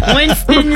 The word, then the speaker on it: Winston-Salem: Like Winston-Salem State. – You butchered that whole thing. – Winston-Salem. – Like Winston-Salem, yes.